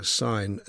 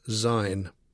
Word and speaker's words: Syne: Through the crackles, he can be heard making the common mistake of pronouncing sign as zine.